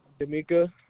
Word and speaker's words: Damieka: D'Amica. – How do you spell that, please? – D-A-M-I-E-K-A.